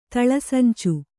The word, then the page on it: ತಳಸಂಚ: taḷa sanca